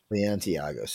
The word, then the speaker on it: Balenciaga: Balenciaga.